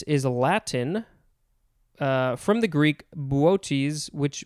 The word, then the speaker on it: Boötes: All right, next we have, I think it is pronounced Bootes, something like that. It is spelled capital B-O-O-T-E-S, but the second O has the umlaut, which is the two dots.